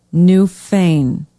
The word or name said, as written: New Fane